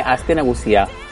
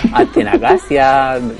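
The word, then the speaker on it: Aste Nagusia: Yo lo vasco, lo que me costó a mí aprenderme Astenagusia. Que yo decía de todo. Decía Astenagasia. Me inventaba.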